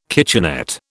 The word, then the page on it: kitchenette: British English